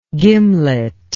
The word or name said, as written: gimlety